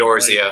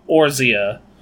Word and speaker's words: Eorzea: Eorzea. – Like, Eorzea.